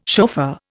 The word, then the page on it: chauffeur: Indian